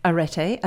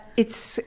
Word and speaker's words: Arete: So this the festival that you're doing now, Arete. I've said it wrongly again. Arete, isn't Arete. – It? – Arete.